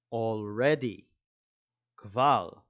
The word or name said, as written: already